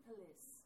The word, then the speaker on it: pelisse: Her reconstruction of Jane Austen's police coat, pelisse, how do you pronounce that? – I say pelisse. – Pelisse? – Yeah.